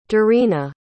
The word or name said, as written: darena